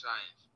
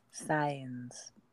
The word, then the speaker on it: Sainz: Is it Sainz? Carlos Sainz? – I don't know. Ooh, Wikipedia has a Spanish pronunciation. Hold, please. – Carlos Sainz. Vázquez de Castro. – Did you hear that? – Science.